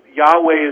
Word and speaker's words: Yahweh: They spoke the name Yahweh. It didn't sound exactly like that, but Yahweh is the closest pronunciation that we can get to the original.